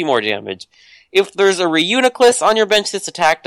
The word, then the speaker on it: Reuniclus: Reuniclus.